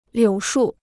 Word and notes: 柳树: liǔ shù

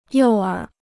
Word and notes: 诱饵: yòu ěr

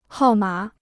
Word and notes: 号码: hào mǎ

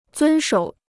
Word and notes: 遵守: zūn shǒu

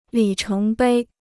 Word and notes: 里程碑: lǐ chéng bēi

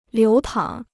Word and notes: 流淌: liú tǎng

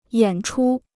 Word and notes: 演出: yǎn chū

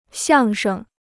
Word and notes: 相声: xiàng sheng

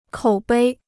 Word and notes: 口碑: kǒu bēi

